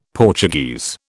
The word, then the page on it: Portuguese: British English